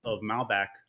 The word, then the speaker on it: Malbec: Like, actually, I think I had a glass of Malbec, if that's the right pronunciation.